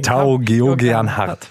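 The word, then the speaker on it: Tao Geoghegan Hart: Tau Georgian Hart.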